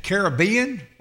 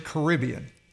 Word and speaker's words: Caribbean: Is it Caribbean or is it Caribbean? – I say it both ways.